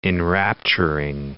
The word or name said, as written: enrapturing